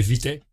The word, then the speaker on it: Vitae: Mas na verdade é Vitae.